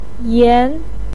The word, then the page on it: yan: 2